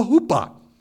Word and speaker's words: huppah: It's called a huppah. H U P P A is the way we'd spell it.